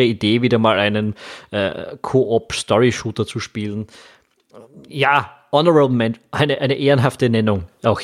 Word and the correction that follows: Honorable Mention: Ja, Honorable Mention kann man dann übrigens auch The Blackout Club geben.